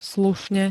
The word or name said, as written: slušne